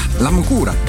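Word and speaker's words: La Múcura: La Mucura, ja. Zo spreek je dat uit, toch? Ja, dat is even kijken.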